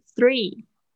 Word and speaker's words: three: 稍微注意一下你的 three. – Three. – 嗯，注意一下你的 th 的发音。 Three, three 啊。